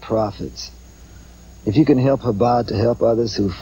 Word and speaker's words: Chabad: Yeah, it's Chabad. Chabad. – Chabad, okay. – Like a little bit of a catch in your throat, you know.